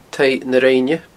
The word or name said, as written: taigh na rinne